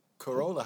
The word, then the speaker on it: Carola: Pronounced Carola, like the car. – Carola, how you doing, babe? – Hello, hi.